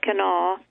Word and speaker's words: Kanawha: Notice, all through our discussion tonight of Kanawha County, we choose to pronounce it the way real people, the precious living humanity of West Virginia, pronounce it, Kanawha.